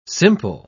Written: simple

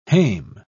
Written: hame

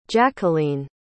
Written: jacqueleen

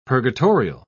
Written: purgatorial

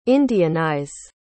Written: indianize

